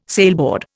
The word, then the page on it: sailboard: Indian